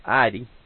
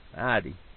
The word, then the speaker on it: Ida: The name would have been pronounced Idy in an Arkansas draw. Idy. Like Elby is Elba is Elby. So